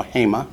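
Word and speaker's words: hema (הֵמָּה): Accents on the first syllable, hema.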